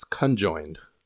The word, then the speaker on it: conjoined: It's conjoined. – Conjoined. – Conjoined, not co-joined. – Not co-joined. – Is co-joined a word? – That's how it's spelled, right? – No, there's an N.